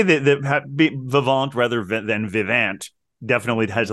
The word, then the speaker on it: Vivant: I confirmed that pronunciation. – Oh, you did. It's Vivant.